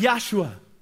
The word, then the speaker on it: Yahshua: And then there's a character that's really introduced named Joshua. And in Hebrew, it's pronounced Yahshua.